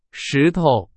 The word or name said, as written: 石头